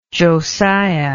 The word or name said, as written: josiah